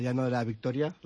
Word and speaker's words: Llano de la Victoria: Sí, pues celebramos que ya llevamos 30 años desde el primer año que bajamos al Llano de la Victoria. ¿Lo he dicho bien, Lorenzo? – Llano, sí, saludamos a Lorenzo Echeto, que está con nosotros también.